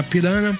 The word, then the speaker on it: Piranha: que se escreve Piranha mesmo, com NH, mas se diz Hanna Pirana,